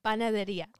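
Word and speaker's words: Panadería: Did I say that properly? – Panaderia. – Panaderia. Okay.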